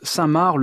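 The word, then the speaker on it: Cinq-Mars: Voici maintenant un Alali, donc c'est la fin de la chasse sonnée par les corps de chasse dans l'opéra Samar de Charles Gounod. Cela s'écrit 5 mars et cela se prononce Samar,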